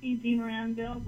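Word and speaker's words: DeMaranville: Marie DeMiranville, wife of a blind husband.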